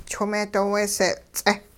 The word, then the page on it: chwing-meedooweesee-ts'eh: hupa